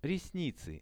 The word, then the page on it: resnicy: Russian